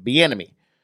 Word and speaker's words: Bieniemy: i looked it up on fucking Google and this is how they pronounce it. Bieniemy.